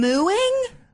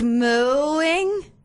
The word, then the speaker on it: mewing: Mewing? Like mooing? – I think it's pronounced mewing. – With a U. – Yes. Not mewing. – Not like mooing like a cow.